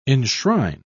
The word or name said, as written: enshrine